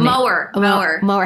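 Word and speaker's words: Moher: Mower.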